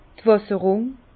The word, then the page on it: d' Wässerrung: Bas Rhin; Herrlisheim